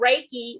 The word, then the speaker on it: Reiki: First of all, Reiki is a two-syllable word, which comes from two Japanese words, which means God's wisdom and life force energy.